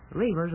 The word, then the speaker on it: Reavers: F-R-E-D, Fred. Reavers is spelled just like it sounds. It rhymes with beavers, but it's spelled differently, naturally.